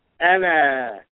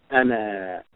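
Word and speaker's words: ana (أنا): So the independent pronouns first, we have ana, aleph with fathah, nun with fathah, aleph lif means the fathah sound, pronunciation, ana, meaning I.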